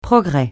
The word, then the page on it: progrès: francais